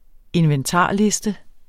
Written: inventarliste

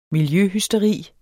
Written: miljøhysteri

